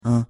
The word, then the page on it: un: French